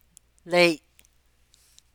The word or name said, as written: léi’